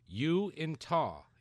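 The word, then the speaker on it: Uinta: It says, the pronunciation of Yointa is actually Uintah. Uintah. It's named after the mountains in Utah.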